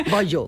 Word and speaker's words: Bayeux: Saltaba la noticia en el periódico The Guardian, que dice así: el Museo Británico permitió una visita privada al tapiz de Bayeux, ¿se pronuncia así? – No, digo yo. Yo es francés, ya sabes que Boyo.